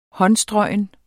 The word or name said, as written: håndstrøgen